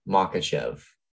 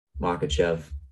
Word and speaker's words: Makhachev: Charles the Bronx Oliveira versus Islam Makhachev. – Makhachev? – I said that right. – Makhachev.